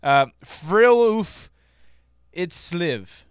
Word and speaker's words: friluftsliv: Frilluf Itsliv. That's how it's pronounced.